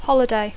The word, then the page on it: holiday: Catalan speaker; intermed